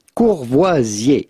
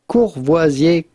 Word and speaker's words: Courvoisier: So how do you go about pronouncing the name of this famous brand with a typical French pronunciation? Courvoisier. Courvoisier cognac.